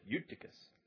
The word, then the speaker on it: Eutychus: Eutychus. Very well pronounced, too.